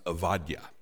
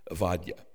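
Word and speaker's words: Avadya (עובדיה): In Hebrew, though, it should be noted that there's no B. In the Hebrew alphabet, it's actually a V. So his name would be pronounced Avadya. Avadya.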